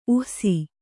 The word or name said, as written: ಊಸು